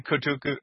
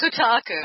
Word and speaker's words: Kotaku: So there's this article again on Kotaku, someplace. – Kotaku. – And I am place that my brain just refused to use the right pronunciation.